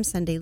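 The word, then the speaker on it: Sondee: I'm on LinkedIn under my name, Sunday Lima, and Instagram and Facebook.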